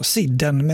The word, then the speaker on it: Sidden: Mats skriver till oss som tipsade om Sidden, alltså Bengt Sidden Andersson då, och skriver egentligen bara att det uttalas så, Sidden med S, småländsk poet och skådespelare, har dock ingen koll på något han gestaltat, skriver han också där.